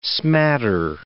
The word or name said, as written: smatter